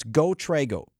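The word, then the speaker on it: GoTrego: Trago is spelled T-R-E-G-O, so it's GoTrago.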